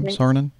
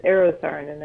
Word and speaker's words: Eero Saarinen: Is that the way you pronounce the architect's name? Saarinen? – Yeah, I can never say his name correctly. It's Eero Saarinen, I think.